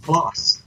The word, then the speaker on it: Duplass: Duplass? – Yeah, the guy from the league. – Duplass.